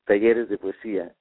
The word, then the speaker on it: Talleres de Poesía: So tell me how to say it. Taleras de Poesía? – Talleres de Poesía. – Ah, okay. Not too bad.